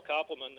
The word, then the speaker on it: Koppelman: It's you know, my name is Carl Koppelman. That's spelled K-O-P-P-E-L-M-A-N.